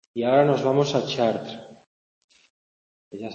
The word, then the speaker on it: Chartres: Bueno, yo seguro que sabéis cómo se escribe, pero yo lo pronuncio fatal. Pero es así, Chartres.